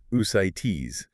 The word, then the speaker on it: ascites: Which, for a listener, is pronounced ascites.